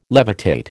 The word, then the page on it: levitate: American English